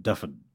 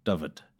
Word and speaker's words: Dyfed: It's D-Y-F-E-D. – Yeah. – God help us with these Welsh pronunciations. But I'm hearing it as kind of a V and an F sound. Dyfed, Dyfed, Dyfed. Yeah. – Dyfed. – Yeah. You got to say it quickly and just throw it out there.